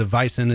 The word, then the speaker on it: Weizen: They call it a Hefeweizen, or that's the unfiltered wheat, or just a Weizen is the wheat beer. – Like bison? – Yeah, except it's W-E-I, and they pronounce it like a V, Like so, like Volkswagen.